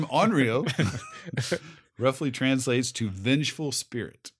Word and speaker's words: onryo: Onryo. – It's like, let's spell it. – O-N-R-Y-O.